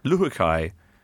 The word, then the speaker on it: Luhukay: Luhukay.